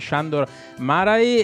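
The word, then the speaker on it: Sándor Márai: di Sandor Marai e Andrea... – Pronunciato giusto? – Sì. Pronunciato bene, okay.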